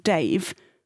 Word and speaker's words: Davy: Well, it's spelled Davy, but apparently it's called Dave because he's a horse of the people.